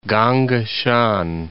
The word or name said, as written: Gongga Shan